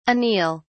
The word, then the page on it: anele: British English